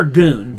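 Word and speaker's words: Suzuki-gun: Is this Suzuki Gun or Goon? – Goon. It's spelled Gun, but pronounced Goon.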